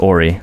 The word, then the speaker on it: Horry: So I just hit a three-peat, fuck three hoes I met this week, Robert Horry. – Silent H.